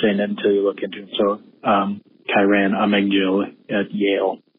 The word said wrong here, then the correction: Amegadjie: How do you say the guy's name? Amegil? Bro, Amegadjie. Come on, man. You guys are just doing this to mess with me. Kyran Amegadji. That's not what you said.